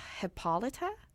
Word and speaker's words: Hippolyta: The two dancers, they played Hippolyta.